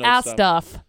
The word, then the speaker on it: ASDF: They're pronounced ass stuff. – Well, I just say ASDF. It's just wrong. – Easier that way. – Ass stuff movies.